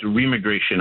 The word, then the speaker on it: remigration: They call it remigration. I always have trouble pronouncing it. It's you put R-E in front of immigration.